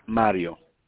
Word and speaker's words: Mario: Mario.